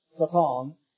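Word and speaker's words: Satan (שָׂטָן): but Satan is how you say it in Hebrew, and this has the definite article before it.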